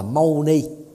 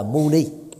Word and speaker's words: muni: Thì người Trung Hoa đã phiên âm lại thành là Mâu Ni, chứ đọc đúng ở trong tiếng Bali đó là Muni.